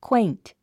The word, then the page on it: quaint: kwéint; クエイント